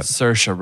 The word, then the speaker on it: Saoirse: Well, currently correcting you on the pronunciation of Saoirse Ronan's name. – How do you pronounce it? – Saoirse. – The way he just said it. – Saoirse, not Saoirse. – Yeah. Yeah. – Saoirse. – Yep. – Saoirse Ronan. – You got it right.